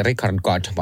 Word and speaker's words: Richard Gadd: Onko se Richard Gadd vai miten se lausutaanko? – Joo.